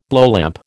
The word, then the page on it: blowlamp: American English